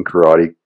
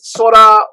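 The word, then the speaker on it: kara (空): He's looking at the first kanji in Karate Kids. – The top one, yeah, kara. Yeah, it can be pronounced as sora or ku or kara.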